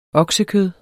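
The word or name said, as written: oksekød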